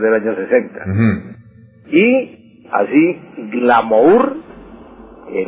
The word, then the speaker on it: glamour: En francés se escribe, como usted dice, glamour, glamour. Pero se pronuncia glamour.